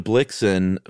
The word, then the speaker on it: Blixen: So Blixum changed to Blixen first, I think, to rhyme with Vixen.